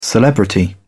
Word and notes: celebrity: British English

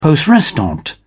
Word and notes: poste restante: British English

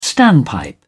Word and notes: standpipe: British English

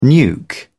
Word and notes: nuke: British English

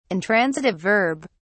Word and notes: intransitive verb: British English

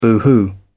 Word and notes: boohoo: British English

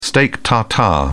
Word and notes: steak tartare: British English

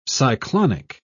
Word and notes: cyclonic: British English

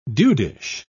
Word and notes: dudish: British English

